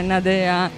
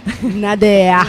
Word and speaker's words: Nadeah: mentre la musique si sta per crollare, che sono stanchissima stasera, vi voglio parlare di Nadea, che non è Nadea in romano, ma in realtà è Nadea. – Nadea è una cantante australiana e si scrive N-A-D-E-A-H, come tipo Deborah con l'H, insomma.